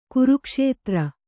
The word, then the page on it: ಕುರುಕ್ಷೇತ್ರ: kurukṣētra; Kannada